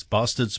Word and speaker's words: Bastaards: It's Bastaards with two A's for those that are listening and trying to work out why we're swearing on exactly.